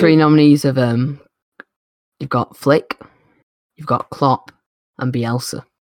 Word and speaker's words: Flick: Flick.